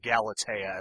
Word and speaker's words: Galatea: Because she runs out of power while fighting Galatea after, or Galatea as I prefer to pronounce it because I think that's actually how it's pronounced.